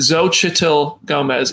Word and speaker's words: Xochitl Gomez: Xochitl Gomez is the correct pronunciation, says Julia Delbell.